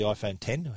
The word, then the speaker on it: iPhone X: iPhone X is otherwise known as the iPhone 10, however you want to pronounce that,